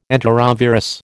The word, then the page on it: enterovirus: American English